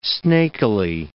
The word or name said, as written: snakily